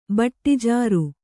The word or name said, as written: ಬಟ್ಟಿಜಾರು